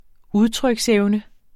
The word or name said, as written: udtryksevne